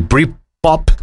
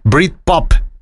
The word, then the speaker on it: Britpop: Britpop de todos los tiempos, lo dije mal, Britpop.